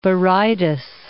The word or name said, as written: Berytus